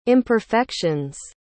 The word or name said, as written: imperfection's